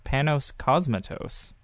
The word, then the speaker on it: Panos Cosmatos: and I apologize to him in advance for uh, how I'm pronouncing it, but Panos Cosmatos. – That's Maybe? right. Yep.